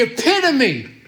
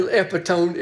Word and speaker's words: epitome: He's the epitome. I can spell it E-P-I-T-O-M-E. The epitome of what really were epitone, it, phonetically, it was like epitone.